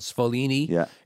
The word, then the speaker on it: Sfoglini: So the original version of Cascatelli is made by a company called Sfoglini in upstate New York. It's spelled S-F-O-G-L-I-N-I. It's the G silent.